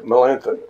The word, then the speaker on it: Melanchthon: And his closest friend was, or one of his friends, is Philip Melanchthon. Melanchthon? Anybody know how I'm pronouncing? – Melanchthon. – Okay, Melanchthon, okay.